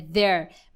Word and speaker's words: their: Muita gente fala their, there, não é their, é their. Mesma pronúncia de there, de there is, there are é, over there, tá? É a mesma pronúncia.